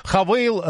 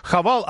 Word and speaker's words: Хавейл: Вот на самом деле Хавал, Хавейл. Оказывается, официальное название все-таки Хавейл, разговорная речь Хавал, а в Китае, говорят, этот бренд и вовсе произносят как Хафу.